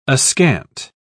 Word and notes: askant: British English

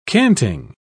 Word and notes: canting: British English

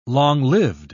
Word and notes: long-lived: British English

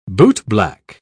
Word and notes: bootblack: British English

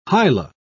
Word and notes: hyla: British English

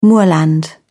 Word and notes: moorland: British English

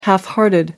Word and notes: half-hearted: American English

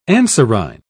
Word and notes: anserine: British English

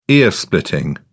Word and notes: ear splitting: British English

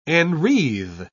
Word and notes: enwreathe: British English

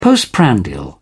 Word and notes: postprandial: British English

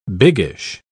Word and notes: biggish: British English

